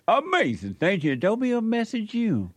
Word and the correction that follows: Messianic: You're leaving out the Anik. – Oh, Messianic. – Messianic. – Messianic. – Yeah, it's Anik. – The root word is Messiah, or the similar word is Messiah.